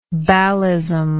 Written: ballism

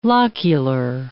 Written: locular

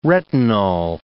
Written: retinol

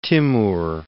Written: Timur

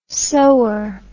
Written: sower

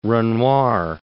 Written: Renoir

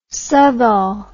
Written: Southall

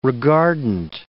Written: regardant

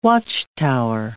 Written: watchtower

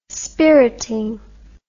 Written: spiriting

